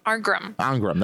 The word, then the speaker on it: Arngrim: And Allison Ingram. How do you say her name? Ingram. – Allison Arngrim. – That's right.